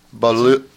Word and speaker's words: Beaulieu: Then we heard The Executioner by Christian Eric Beaulieu. It's a French spelling. B-E-A-U-L-I-E-U. How would you say that? – Beaulieu.